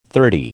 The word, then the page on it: thirty: American English